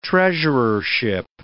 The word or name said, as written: treasurership